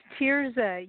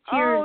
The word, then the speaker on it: Teresa: Oh, it's Teresa. You know, picture hovering your fingers over your face like tears are running down, and my name is Teresa.